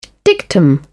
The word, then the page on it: dictum: British English